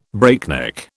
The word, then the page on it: breakneck: British English